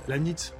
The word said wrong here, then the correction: Leibniz: Bon, mais parce que vous l'avez mal prononcé, c'est Leibniz qui est contemporain de Voltaire, nous sommes d'accord. – Ah, oui, c'est Leibniz.